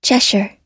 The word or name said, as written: cheshire